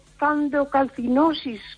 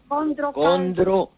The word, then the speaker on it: condrocalcinosis: Pues, y otro, doctor, candrocalcinosis, ¿qué viene siendo? – Condrocalcinosis.